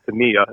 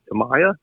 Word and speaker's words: Tamiya: I always called it Tamiya ever since I was a kid. It was always Tamiya. Then I found out later, I'm like, oh, it's Tamiya.